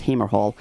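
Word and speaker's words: Hamer Hall: It is actually pronounced Hammer Hall.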